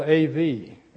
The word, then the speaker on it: Av: And it's spelled A-V. And it's pronounced Av.